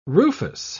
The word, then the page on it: rufous: British English